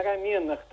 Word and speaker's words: рамена: Правильное ударение «рамена».